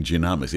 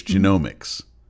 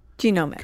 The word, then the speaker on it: genomics: So are you able, and I realize now that I've been mispronouncing the word too, I've been saying genomics, it's genomics. – Genomics.